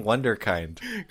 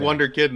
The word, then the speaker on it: Wunderkind: I'm like, why am I saying Wunderkind? – Cuz he said, yeah, Wonder Kid, and they're like, "You know it's not Wonder Kid, right?"